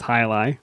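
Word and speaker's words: Jai Alai: It looks like JLA, but it's pronounced Hyalai.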